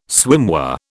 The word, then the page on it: swimwear: British English